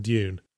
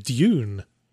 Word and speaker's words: Dune: I pronounce it Dune. – Dune? – Like there's a Y in there.